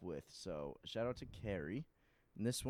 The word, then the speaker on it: Khary: Carrie? Like Carrie. – The blood one? – So, K-H-A-R-Y is how you spell it.